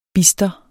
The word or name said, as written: bister